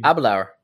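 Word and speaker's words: Aberlour: We're going to be trying all 4 of them out, including the booster, Avalure 12. Or I'm sorry, Avalure 16. 16. – So that's not how you pronounce it, apparently. It's what do you mean? – Abelour.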